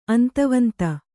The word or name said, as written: ಅಂತವಂತ